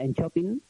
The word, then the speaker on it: Enköping: Se pronuncia Enchopin. – Enchopin. – La K, eso es. Se pronuncia como una Che, que diríamos, Enchopin.